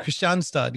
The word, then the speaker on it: Kristianstad: It's in Krinistjad, isn't it? Am I saying that right? – Kristianstad.